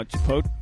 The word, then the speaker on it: Chipotle: Pick a place near Chipotle. By the way, am I pronouncing that wrong? – No, Chipotle.